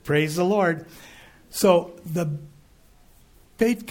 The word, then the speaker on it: Beit: They call them Beit Knesset. The Beit Knesset. Now, Beit could be pronounced Beth.